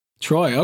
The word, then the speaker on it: Treuille: And my last name is pronounced Treuille, T-R-O-Y. – Treuille, okay.